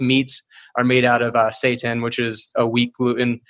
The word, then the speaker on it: seitan: Things like, it's pronounced seitan, but it's not what you think it is.